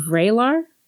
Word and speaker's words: Vraylar: Vraylar? It's V-R-A-Y.